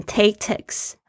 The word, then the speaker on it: tactics: 第一个就是 tactics，战术策略，t a c t i c s，tactics，注意一下那个 a 的声音，不要念成 tactics 啊，tactics 那个 a 是 a 的声音，然后那个 x 是非常轻的，它这边里面的 c 的 k 对很轻，tactics 就是战术策略，get your hands on 是一个。